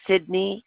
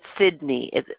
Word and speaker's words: Cydni: Okay, first name is Sydney. It's spelled C-Y-D-N-I. The last name is Donahue, D-O-N-O-H-U-E.